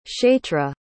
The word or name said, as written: chaitra